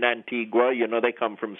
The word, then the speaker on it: Antigua: Well, here in Antigua, we say Antigua. It's probably the only silent U in the English language because it's originally a Spanish word.